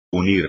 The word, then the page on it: unir: espagnol